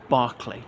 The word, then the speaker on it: Berkeley: And that was him, became a rather wealthy man, was elected mayor of his town of Berkeley, as in B-E-R-K-E-L-E-Y. But I think it's pronounced Barkley, not Berkeley, as in there's a famous Barkley Castle there, but spelt as in Berkeley, California, or, you know, the philosopher George Berkeley.